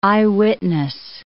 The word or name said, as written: eyewitness